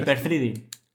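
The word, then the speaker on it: Hyper-Threading: hiper trading, ¿cómo se pronuncia? El hipertreading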